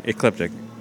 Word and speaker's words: Ecliptic: Ecliptic.